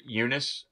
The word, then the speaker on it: Eunice: This is the second and final film to feature Eunice.